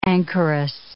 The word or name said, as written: anchoress